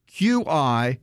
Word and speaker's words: Qi: So when you buy a charger, make certain that it is QI certified. Now, QI is pronounced Qi. Make certain it's pronounced Qi certified and that's the new standard for wireless energy transmission.